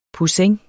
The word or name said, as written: poussin